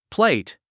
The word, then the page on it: plate: pléit; プレェイト